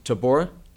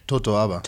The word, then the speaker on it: totoaba: the Tabora? – Totoaba.